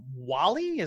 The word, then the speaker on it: Whalley: Directed by Ron Howard, starring Val Kilmer, Joanne Whalley. Is that how you say her name? – Yeah, actually, and at the time, Joanne Whalley Kilmer.